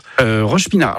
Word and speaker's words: Roche-Pinard: Et à Roche-Pinard. Alors, c'est ça, hein, c'est bien comme ça qu'on prononce. – Roche-Pinard.